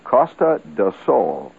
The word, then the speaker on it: Costa do Sol: And it's Costa do Sol. Costa do Sol. It's spelled C-O-S-T-A, Costa, Coast, D-O, of, Sol, S-O-L. Sun means the coast of sun, the sun coast.